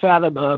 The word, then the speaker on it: Fatima: Well, it's Aleister Crowley, and uh, the town is called Fatima, but it's named after, uh, it was during the Moorish occupation of Spain, and it was named after Fatima in Arabic, uh, the daughter of Muhammad, upon whom be peace.